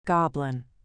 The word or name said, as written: goblin